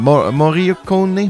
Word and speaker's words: Morricone: Les images de Sergio Leone, l'ambiance musicale de Denio Moccione, euh, Morricone, Morricone, Ennio Morricone, j'ai toujours de la misère à, le prononcer.